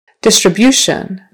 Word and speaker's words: distribution: Distribution. Now the emphasis is on the third syllable.